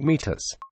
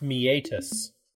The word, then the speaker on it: meatus: The earbud should fit snugly inside the external acoustic meatus. – I believe it's pronounced meatus.